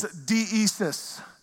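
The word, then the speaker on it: deesis (δέησις): The Greek word for this is deesis.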